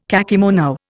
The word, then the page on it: kakemono: Indian